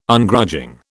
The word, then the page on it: Ungrudging: British English